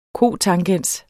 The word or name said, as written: cotangens